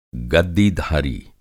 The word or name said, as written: गद्दीधारी